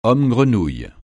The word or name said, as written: homme-grenouille